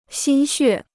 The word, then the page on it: 心血: xīn xuè